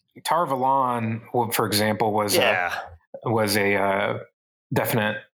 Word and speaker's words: Tar Valon: I suspect some of these are the way Jordan intended, because Tar Valon was apparently the way Jordan intended. He saw it was a take on Avalon.